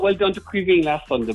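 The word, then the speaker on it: Caoimhín: You might call him Keeney. I said, no, it's Queeveen, boy.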